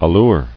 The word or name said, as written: Allurer